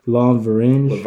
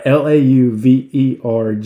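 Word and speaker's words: Lauvergne: Joffrey Laverne. – That's how it's pronounced. Wow. All right, Laverne. It's pronounced Laverne.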